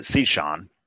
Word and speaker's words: Cichon: Um, he says Sishan. – Okay. – Sometimes Sishon. He's actually I've heard him pronounce it two different ways. We actually pronounce it Sishan.